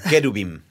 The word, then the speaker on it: kerubim: Porque si tratamos de encontrarlo, si lo encontramos por casualidad, nos vamos a topar con un jerubim. ¿Cómo se pronuncia? Eh, Kerubim. Kerubim.